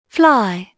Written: fly

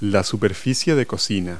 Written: cooktop

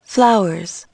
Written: flowers

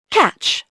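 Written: catch

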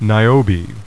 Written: Niobe

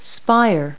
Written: spire

